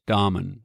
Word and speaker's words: Damen: Here in the U.S., I refer to it as Damen because that's how it is phonetically spelled. But it's a family name, and it's pronounced as Damen.